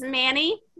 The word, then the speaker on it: Mani: So my Instagram is Manny, like manicure, M-A-N-I dot booked, B-O-O-K-E-D.